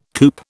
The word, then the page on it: coop: British English